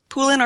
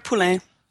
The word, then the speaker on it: Poulin: Is it Poulin? – Poulin or Poulin. Either or. – Depending on how French you feel.